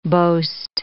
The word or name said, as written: boast